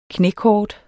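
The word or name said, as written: KNÆKORT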